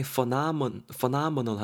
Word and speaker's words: phenomenal: P H E N O M E N A L phenomenal 이렇게 발음이 되고요. 여러분 ph는 f로 발음해야 됩니다. p가 아니고 f 이렇게 발음해야 돼요.